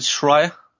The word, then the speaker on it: Schreier: Is it Schreier? Yep. I got it right.